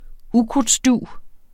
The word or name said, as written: ukrudtsdug